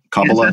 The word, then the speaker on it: Kabbalah: Am I pronouncing that right? Kabbalah. – Yes, that's right.